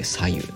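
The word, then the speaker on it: 白湯: 白い湯と書いて左右と読み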